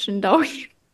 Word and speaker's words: Jindaouis: J-I-N-D A-O-U-I-S Schindaui.